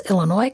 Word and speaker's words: Illinois: I remember making a face when someone pronounced the S on the end of Illinois when I was a child. And That person did learn how to pronounce Illinois correctly, but he never spoke to me for the next 5 years.